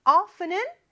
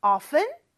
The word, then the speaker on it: often: 뭐 이런 말이었는데 visit이 뭐 물론 방문하다 라는 좀 딱딱한 말도 되지만 그냥 가다 라는 의미로 많이 쓰이고 often은 종종이라는 뜻이고 발음은 두 가지가 있다고 그랬죠. often, t 발음 없이 할 수도 있고 often, t를 발음할 수도 있다.